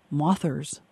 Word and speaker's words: Mothers: Tell us about Nick and Jim and the people who are the Mothers. That's M-O-T-H-E-R-S, but it rhymes with authors.